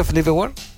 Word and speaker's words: Felipe Ward: Agregar algo más, que durante de la semana también se salió otro video que es Felipe Ward, que es, no sé si me está bien dicho, Felipe Ward. Sí, Felipe Ward.